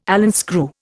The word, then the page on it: Allen screw: Indian